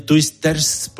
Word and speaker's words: Twisters: Lo has pronunciado muy bien, Twisters,